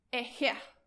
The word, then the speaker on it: Ehyeh: The name that was given to Moses at the burning bush when he was talking to him was this word here, E-H-Y-E-H. It's pronounced Eheh.